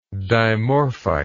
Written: dimorphite

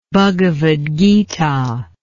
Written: Bhagavad Gita

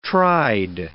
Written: tried

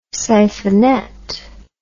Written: siphonet

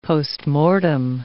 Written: postmortem

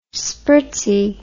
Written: spritzy

